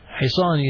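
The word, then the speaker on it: hisan (حصان): Hisan is horse. Hisan, horse. That is hisan. Hisan. This is how you pronounce it. I hope you're writing down. Ha, sad, alif, and noon. Hisan.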